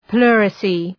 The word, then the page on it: pleurisy: ‘plʋrısı